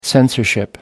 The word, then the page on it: censorship: American English